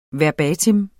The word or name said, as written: verbatim